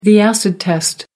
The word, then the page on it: acid test: American English